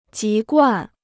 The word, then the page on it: 籍贯: jí guàn